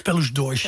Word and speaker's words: Pelos Dois: En jij zei dat moet zijn Amar Pelos Dois. Zeg ik dat goed? – Pelos Dois. – Pelos Dois van Salvador Sobral.